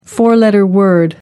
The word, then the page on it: four-letter word: American English